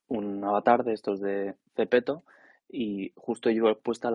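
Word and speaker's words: Zepeto: Y Zara se ha metido de la mano de Cepeto. Cepeto es una aplicación que se escribe con Z, lo podéis buscar en, creo que está para Android y para, para para ellos está seguro.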